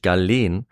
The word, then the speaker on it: Galen: Ja, nachdem ich das eine Folge lang falsch ausgesprochen habe, weiß ich es mittlerweile zum Glück, nämlich Galen.